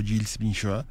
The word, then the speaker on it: Gilles Binchois: El 20 de septiembre, pero de 1460 hace 557 años, falleció el compositor flamenco Gilles Binchois, se pronuncia así, eh, es francés,